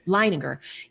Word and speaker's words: Leininger: And James, I think she mentioned it earlier, but James Leininger or Leininger, how do you say the last name again? – Leininger.